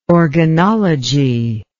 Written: organology

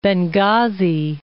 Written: Benghazi

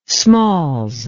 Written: smalls

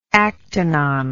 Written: actinon

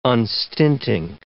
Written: unstinting

